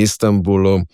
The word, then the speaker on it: Istanbul: Ker seveda se Istanbul izgovori z mejem, ker ga B vpliva na ta nosnik, ne?